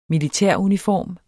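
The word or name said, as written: militæruniform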